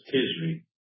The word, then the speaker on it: Tishri: I say Teresi. – It's Tishri. – Huh? – Tishri. – Tishri. – Yeah. – Okay, Tishri.